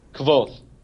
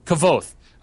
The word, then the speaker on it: Kvothe: Is it Kvothe? – Kvothe.